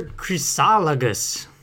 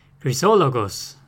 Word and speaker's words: Chrysologus: Peter Chrysologus, or Chrysologos in the Greek one. This is the Latin pronunciation.